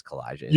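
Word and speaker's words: Calijah: And I hope I pronounced that right, but it is Kalijah. – You did. Yes. – Nice. – Yes, you pronounced that perfectly.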